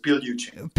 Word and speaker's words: Pigliucci: The G is silent, so it's Pigliucci.